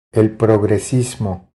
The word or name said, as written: El progresismo